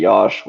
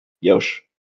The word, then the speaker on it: Yosh: So basically it was like Josh, and then it was like pronounced D DJ Yosh, which kind of like would make sense. And then, but it was spelled Y-O-S-H, which then people would pronounce Yosh.